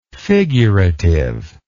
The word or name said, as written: figurativeness